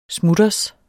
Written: smutters

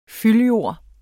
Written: fyldjord

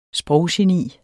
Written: sproggeni

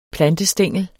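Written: PLANTESTÆNGEL

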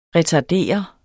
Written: retardere

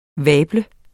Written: vabels

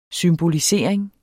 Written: symbolisering

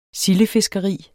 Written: sildefiskeri

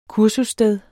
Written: kursussted

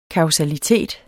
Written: kausalitet

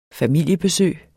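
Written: FAMILIEBESØG

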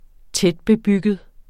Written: tætbebygget

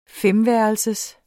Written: femværelsers

